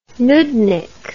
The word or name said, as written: noodnik